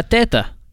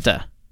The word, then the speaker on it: Teta: Teta. – Say that again. – That's Arabic for grandmother. – Say that again. – Teta. Like T-E-T-A. Teta.